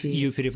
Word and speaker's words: Юферев: Но ты знаешь, я слышала, что уже питерский мундеп Никита а, Юферев или Ю... – Юф... Юферев, да, ты правильно фамилию ставишь, Юферев. ударение ставишь.